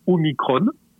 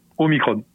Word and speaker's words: Omicron: Ils sont formels il faut dire Omicron pour une raison simple, expliquée, c'est comme epsilon. Voilà. En grec, euh, quand un mot se termine par on, on prononce on, Omicron.